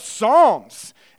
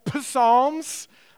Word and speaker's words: Psalms: It's actually a good time to join with us because we're starting a new series this morning in the book of Psalms. And yes, the P in Psalms is silent. So if you've ever called them the P-Psalms, that's okay.